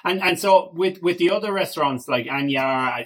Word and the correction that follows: Aniar: it, that's how we pronounce it, Anyar, isn't it? Or Anyar? Oh, Aniar. Aniar, sorry, sorry, Aniar.